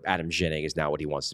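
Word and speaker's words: Ginning: That's actually the way you're supposed to pronounce his name is Yining. He said he wants to be called Jennings, so fine, you're Jennings.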